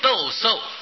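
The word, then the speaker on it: 抖擞: 头陀心意叫做度多，旧意叫做陀陀，翻成中国话呀就是斗兽，手字旁一个斗字，伸斗的斗，手字旁一个树木的树，就是念作斗兽，就是好像这个衣服脏了，把它哈这个整动一下，把这个脏东西弄把它弄掉下来，斗兽啊。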